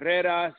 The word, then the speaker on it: Herreras: I get abused sometimes because when I started, I was calling them Herrera instead of Herreras. I was pronouncing the H and not the H.